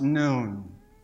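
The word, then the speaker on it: noon (نون): letter N, pronounced noon.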